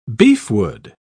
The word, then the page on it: beefwood: British English